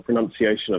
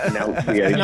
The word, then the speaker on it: pronunciation: Literally, it's pronunciation, not pronunciation. – No, I'm so with you. Yeah. It's N-U-N, none. Pronunciation.